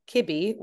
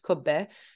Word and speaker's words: kibbeh: So when we make our kibbeh, which sometimes you'll hear pronounced kubbeh, that's usually ground lamb or ground beef mixed with bulgur wheat, which we say budagul in Arabic.